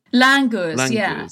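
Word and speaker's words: langurs: But you spent some time with a scientist and with some, is it Langers? Is that how you pronounce it? – Langers, yeah.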